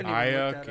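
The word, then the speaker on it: Aiyuk: Am I pronouncing that correctly? Is it Ayuk? I've been calling him Ayuk all year. – Ayuk.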